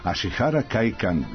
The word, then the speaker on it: Ashihara Kaikan: ali Ashihara Kaikan, nadam se da sam dobro izgovorio. Sandra Spasić, dobar dan, Sandra. – Dobar dan. – Radio sam na izgovoru, da li se osjeća pristup malo japanskom jeziku? – Odlično ste izgovorili, ja upravo ste spomenuli veštinu kojom sam se pre bavila, pre nego što smo ovu našu veštinu doveli u grad.